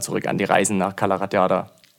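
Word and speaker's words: Cala Ratjada: Es müsste Calaratiada sein. – So einfach spricht man das, Calaratiada? – Ja, okay.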